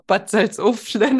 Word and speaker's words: Bad Salzuflen: Nee, wie wird das ausgesprochen? – Doch, Bad Salzuflen. Das ist ein Kurort in der Nähe von Bielefeld.